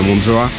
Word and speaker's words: Montreux: Reunidos frente a su estatua en Montreux, o la pronunciación, ¿verdad?, en francés sería Montreux.